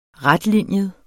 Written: retlinjet

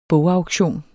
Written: bogauktion

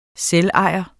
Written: selvejer

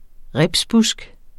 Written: ribsbusk

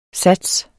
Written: sats

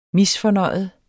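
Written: MISFORNØJET

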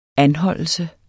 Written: anholdelse

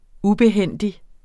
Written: ubehændig

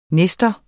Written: nestor

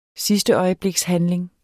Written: sidsteøjeblikshandling

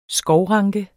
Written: skovranke

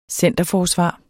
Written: centerforsvar

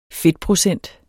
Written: fedtprocent

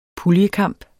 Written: puljekamp

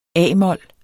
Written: a-mol

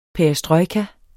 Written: perestrojka

